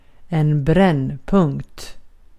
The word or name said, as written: brännpunkt